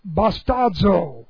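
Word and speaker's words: bastazo (βαστάζω): Present active infinitive of bastazo. B-A-S-T-A-Z long O.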